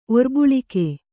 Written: ಒರ್ಬುಳಿಕೆ

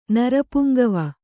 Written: ನರಪುಂಗವ

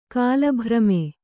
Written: ಕಾಲಭ್ರಮೆ

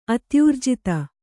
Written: ಅತ್ಯೂರ್ಜಿತ

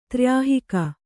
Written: ತ್ರ್ಯಾಹಿಕ

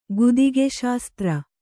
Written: ಗುದಿಗೆಶಾಸ್ತ್ರ

